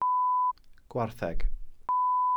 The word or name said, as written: gwartheg